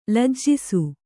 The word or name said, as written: ಲಜ್ಜಿಸು